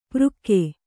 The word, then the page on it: ಪೃಕ್ಕೆ: přkke